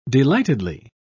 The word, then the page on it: delightedly: British English